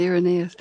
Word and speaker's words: Irenaeus: Now, you credit Irenaeus, am I saying his name right? – Yes, Irenaeus.